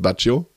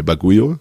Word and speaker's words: Baguio: Es gibt eine Sorte, die heißt Baggio. Ja? Die schreibt man B-A-G-G-I-O. Ja? Meine Bagujo schreibt man B-A-G-U-I-O. Ja? Deswegen heißt es nicht Baggio.